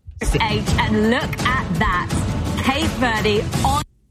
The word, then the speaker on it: Cape Verde: But the Cambridge Dictionary says it's Cape Verde.